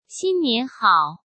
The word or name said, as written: xin nian hao